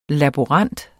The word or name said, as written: laborant